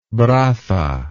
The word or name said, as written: braza